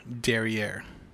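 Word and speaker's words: derrière: D E R R I, E with the little thing on top, R E, derriere.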